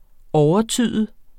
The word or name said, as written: overtyde